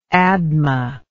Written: Admah

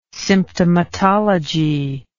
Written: symptomatology

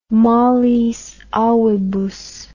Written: malis avibus

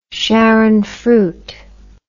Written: sharon fruit

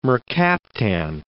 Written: mercaptan

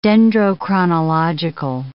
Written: dendrochronological

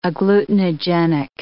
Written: agglutinogenic

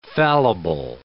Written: fallible

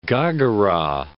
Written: Ghaghara

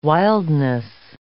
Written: wildness

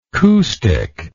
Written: coup stick

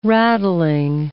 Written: rattling

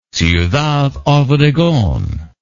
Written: Ciudad Obregon

